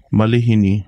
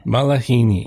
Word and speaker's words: malihini: So that's a um common I don't want to say mispronunciation, but yeah, a lot of people think there's an actual A after the the L, but it's I, so it's going to be Malihini. Malihini.